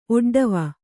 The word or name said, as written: ಒಡ್ಡವ